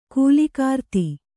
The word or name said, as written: ಕಾಲಕೀರ್ದಿ